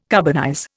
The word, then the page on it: carbonize: Indian